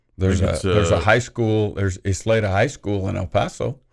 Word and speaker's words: Isleta: It's, and I may be mispronouncing this, it is Isleta. Isleta. – I believe that's Isleta.